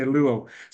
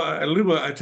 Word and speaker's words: Liubo: And you can say Liubo, the easiest way, just like two syllables, Liubo. – Okay, Liubo. So Liubo,